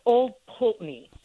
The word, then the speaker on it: Pulteney: It's actually pronounced Old Pulteney. – Pulteney. – Pulteney. – Old Pulteney. – Yeah, we're very frugal in Scotland, so we didn't want to waste the vowels, even though we're there, kind of all stuffed together.